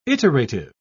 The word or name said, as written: iterative